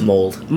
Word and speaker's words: Mould: How do you say it? Mould? – Mould. – Mould. Bob Mould.